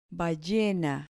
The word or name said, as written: ballena